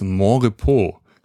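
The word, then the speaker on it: Monrepos: Ich hatte mich kurz gefragt, wie es ausgesprochen wird, aber ich hatte so die Vermutung, dass irgendwie Französisch ausgesprochen wird, ne? – Ja, wird es auch. – Ja, genau, Mont-Repos. Deswegen Montrepos, nicht Montrepos.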